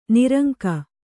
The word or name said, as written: ನಿರಂಕ